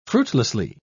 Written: fruitlessly

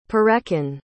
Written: Perekin

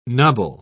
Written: nubble

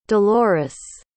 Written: dolores

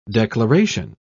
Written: declaration

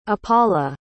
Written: apala

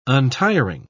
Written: untiring

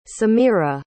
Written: samira

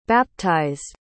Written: baptized

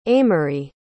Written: amory

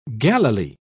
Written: galilee

